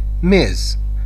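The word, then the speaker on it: Ms: میز. نه میسیز، نه میس، نه میز، هیچ کدوم تلفظ نمیشه. میز.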